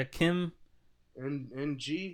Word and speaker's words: Kim Ng: Yeah, Kim. – NG, is that right? – Yeah, I don't know where. I don't know. Oh, it's pronounced Ng. Kim Ng.